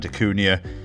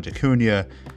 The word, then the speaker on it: DaCunha: I am your host, Fernando John DeCunha, aka the Mortgage Maverick. Please follow me on Instagram at Mortgage Maverick, MortgageMaverick, or via Facebook at Fernando John DeCunha. Last name is spelled DACUNHA.